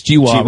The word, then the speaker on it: GWAS: How do you say it? It's GWAS, right? – Screen, GWAS. Yeah.